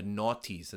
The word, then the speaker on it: noughties: From the noughties, as I've learned, that's how you say it the other day.